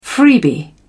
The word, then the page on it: freebie: British English